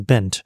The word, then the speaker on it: bend: 像我们英文有一个 b a n d 啊，它叫做 band，那 b e n d 呢，这是 bent。那其实呢，a 的这个发音呢，它比较偏向是在你的梯形的靠近下巴的地方，也就是前端的下缘，所以它念法呢，会是变得。 a band，而 b e n d 的 a 它比较是中间，然后是在前端，所以呢，它应该是 bent。